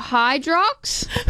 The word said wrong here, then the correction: Hyrox: How do you say it? – Hyrox. Hybrid rock star.